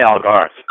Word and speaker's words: Algarve: Is it Algarve? How do you say that? Algarve? – I say Algarve. Algarve. Yeah. Algarve. That's right.